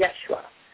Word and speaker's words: Yeshua: Yeshua. I was duly. I got my hand slapped a couple of times because I was not saying it correctly. It's Yeshua.